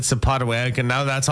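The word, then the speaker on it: Sapotaweyak: We were saying Sapatawet. How did you say it? – Sapatawayak. – Sapatawayak.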